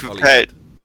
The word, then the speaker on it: Holyhead: I believe that's pronounced Holyhead.